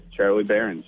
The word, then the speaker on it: Charlie Berens: Morning sickness, Brian and Gene talking to Charlie Berens. Am I saying that right? – Yeah, you got it, Charlie Berens.